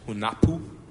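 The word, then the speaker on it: Hunahpu: I tend to call it Hunapu, but its actual pronunciation is Hunapu. But it's so much work to put that much emphasis on the syllable.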